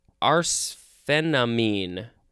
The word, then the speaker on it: arsphenamine: We are going to move on to arsphenamine. Let's double-check the pronunciation. Arsphenamine. Yep. Arsphenamine. This is a noun from 1917.